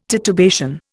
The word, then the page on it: titubation: Indian